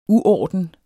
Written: uorden

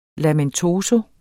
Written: lamentoso

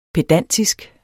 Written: pedantisk

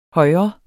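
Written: højre

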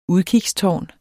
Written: udkigstårn

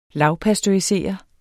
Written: lavpasteurisere

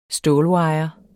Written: stålwire

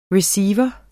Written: receiver

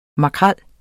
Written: Scomber scombrus

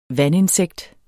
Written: vandinsekt

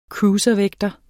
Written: cruiservægter

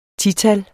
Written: tital

